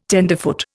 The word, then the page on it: tenderfoot: Indian